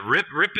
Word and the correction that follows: Ryp: Or Reap. I keep wanting to do Rip, but the correct pronunciation is Reap.